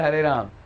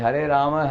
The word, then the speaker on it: Hare Rama: It is not Hare Ram. It is Hare Rama, Hare Rama, Rama, Rama, Hare Hare.